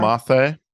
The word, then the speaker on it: moce: Mathe. – Nisa Mathe. – Which is spelled M-O-C-E, but the C, I guess, is pronounced more like the kind of a Barcelona sort of thing.